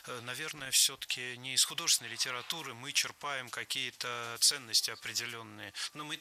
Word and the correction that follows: черпаем: Черпаем, неверно. Правильно, черпаем. Здесь ударение тоже должно падать на корень слова.